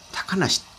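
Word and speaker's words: 小鳥遊: 小鳥に遊ぶって書いて、小鳥優と書いてですね、高梨と読むんですね。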